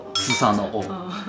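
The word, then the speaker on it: スサノオ: 苏萨的王在日语里叫斯萨诺哦。我们在日本神话体讲过啊，日本神话有两个核心的神，一个叫天照大神，一个叫须佐之男。我说须佐之男这个神啊，名字好奇怪啊，人都叫什么神，这个神那个神，他叫须佐之男。须佐之男日语怎么读？斯萨诺哦。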